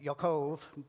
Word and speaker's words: Yaakov: Laban would be the Hebrew, but that sounds a little uppity. And Yaakov, but I'll say their English pronunciation.